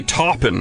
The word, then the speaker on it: Taupin: Is that how you pronounce it? Taupin? – I always say Taupin.